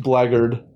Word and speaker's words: Blackguard: I do agree it should be pronounced Blackguard, but that's Brandis bait right there.